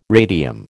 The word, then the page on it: radium: American English